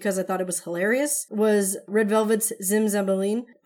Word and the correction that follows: Zimzalabim: How do you pronounce it right? – Zimzalabim. – Oh, Zimzalabim.